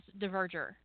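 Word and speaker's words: Dverger: It's D-V-E-R-G-E-R. I'm pretty convinced, actually, that the D probably should be silenced. but I can't make myself make it silent when I say it. So, Diverger. But it could be Verger, or I don't know. But I say Diverger.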